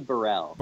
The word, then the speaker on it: Brhel: It's actually Brhel.